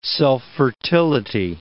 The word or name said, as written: self-fertility